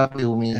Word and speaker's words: Viu: VIU วิวครับวิวมี